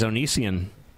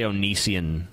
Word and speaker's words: Onision: You have That's another thing people don't like the way I pronounce, because I say Onision. – Yeah, it's Onision. – I guess I'm supposed to be Onision. Yeah, yeah.